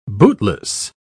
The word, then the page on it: bootless: British English